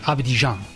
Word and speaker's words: Abidjan: Abidjan.